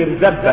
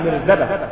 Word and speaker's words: مرزبة: في رواية وبالله اسمع أن عبد الله بن رواحة رأى ملكا معه مرزبة، مرزبة يعني مطرقة، لا تنطق بالتشديد مرزبة، لا، وإنما مرزبة،